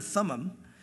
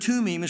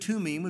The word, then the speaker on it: Thummim: But Urim and Tumim is probably the proper pronunciation because I-M is im in the Hebrew, and it's plural. And THs are not pronounced. They're pronounced more of, instead of th, it's a t sound. So probably Urim and Tumim would be a better pronunciation if you want to be accurate.